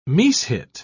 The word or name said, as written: mishit